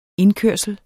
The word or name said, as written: indkørsel